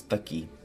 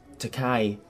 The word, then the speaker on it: Takai: The Arabic pronunciation is Taki. – Right. – But people can't really pronounce that, unfortunately. So growing up, I kind of went with the... I used to watch a lot of manga cartoons, and it's got two pronunciations, Takai or Taki. And I always liked Takai better.